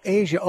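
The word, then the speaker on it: Aja: Is that your name, Ashia? – It's Asia. – Asia? Okay. – Asia, like the continent. – Oh, Asia.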